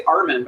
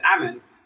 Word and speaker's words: Amann: I'm really excited to have here today Camille Austin from La Loba and Kitty Amann from, I always pronounce it here, I always say Armin, but it's Amann.